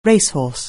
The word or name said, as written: racehorse